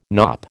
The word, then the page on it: knop: American English